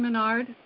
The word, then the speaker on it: Maynard: This is Allison Menard. My last name is spelled Maynard, M-A-Y-N-A-R-D.